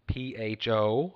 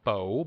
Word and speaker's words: pho: P H O. I learned on the journey that the proper pronunciation is not fo, but pho.